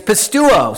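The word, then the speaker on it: pisteuo: It's spelled similar but pronounced a little differently. It's pistuo.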